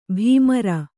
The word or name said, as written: ಬೆಮರ್